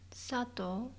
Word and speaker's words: Sato: Sato.